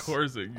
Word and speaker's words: Porzingis: What did I say? Kristaps Porzingis? – Porzingis. – I think that's correct. That's how they pronounce it in Latvia.